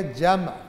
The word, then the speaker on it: جمع: یہ اصل میں عربی کا لفظ ہے، یہ ہے جمع، میم ساکن ہے، ہم اردو میں کہہ دیتے ہیں جمع، جمع کرنا، کیونکہ میم ساکن بولے تو ذرا تلفظ مشکل ہو جاتا ہے.